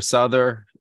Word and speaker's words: Souther: Souther.